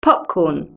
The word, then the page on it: popcorn: British English